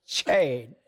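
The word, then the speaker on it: Ché: So it's pronounced Che, right? – But you were saying, yes, Che.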